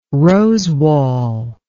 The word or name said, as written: rosewall